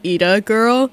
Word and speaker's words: Yde Girl: Yeah, we have to issue a correction, as it's pronounced Ida Girl.